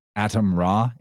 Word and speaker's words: Atem: Atom Ra, A-T-E-M, but it's phonetically the same as Adam. Atom, Adam. Basically, people just say Adam.